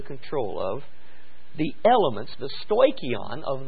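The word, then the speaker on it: stoikion (στοιχεῖον): The Greek word for elements, stoikon, okay, we would spell that S-T-O-I, stoi, S-T-O-I, C-H, and then E-I-O-N. So S-T-O-I-C-H-E-I-O-N, stoikion.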